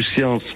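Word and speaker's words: Cians: Et il y a les deux gorges qui sont parallèles, la gorge du, les gorges du Science et les gorges de Dalui. Et le Science, on prononce le S, et Dalui, on ne le prononce pas.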